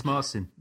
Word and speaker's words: Marcin: And as we said, the Polish composer's name is tricky for us ignorant English types to pronounce, but his first name is Marcin. Or it could be Markin. I could be getting that wrong as well. – No, it is Marcin.